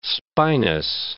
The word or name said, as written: spinous